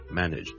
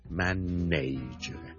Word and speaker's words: manage: 萧叔今日想同大家讲 manage 呢个字嘅发音，manage M A N A G E manage。由于呢个字后面 N A G E 咧，nage 咧。是串做 N-A-G-E 很多人就会将它读成 manage 但是事实上呢我这个字是读 manage nudge nudge nudge 你当是好像 N-I-D-G-E 那样 OK manage 譬如 an establishment that is managed very well 这个地方是 managed run 得很好的 manage 或者 he's the manager manager 也是 he's The assistant manager, assistant, 那个助理经理。